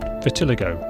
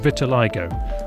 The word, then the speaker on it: vitiligo: But it's not pronounced vitiligo, as I said last time, but vitiligo.